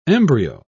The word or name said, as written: embryo